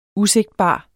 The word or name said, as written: usigtbar